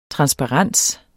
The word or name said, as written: transparens